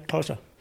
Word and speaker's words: Xhosa: Yeah, Xhosa.